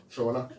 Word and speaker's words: Shona: Shona. Is that the pronunciation? – Yeah. – Shona.